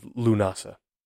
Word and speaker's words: Lughnasadh: August 1st, and it is, I'm probably going to mess up the pronunciation of this as well, Lunasa. There are a few different spellings of it, Lughnasadh, Lughnasa, or L-U, and it has like a, I don't know what it's called, it's like a dash above the U, N-A-S-A. But they have it written out the way you would pronounce it. I'm believing the way it would be pronounced is Lunasa.